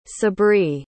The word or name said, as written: sabri